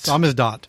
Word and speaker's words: Samizdat: Samizdat. Thank you for pronouncing that for me.